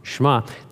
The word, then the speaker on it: Shema: I was corrected on that. I was pronouncing it wrong, Shema.